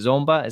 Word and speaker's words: Zomba: That is something that you guys have started around with the NAMI Congo Mission there in Zomba. Malawi. Am I saying it correctly? Is it Zomba? Is that how you say it? – Yep, that's correct.